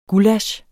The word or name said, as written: gullasch